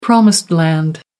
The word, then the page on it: promised land: American English